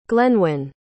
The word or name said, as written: glenwen